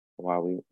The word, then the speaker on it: Huawei: It's going to be in the Android Play Store, as well as Huawei, which I always have trouble pronouncing. It's Huawei. Okay, that sounds right.